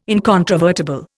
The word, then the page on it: incontrovertible: Indian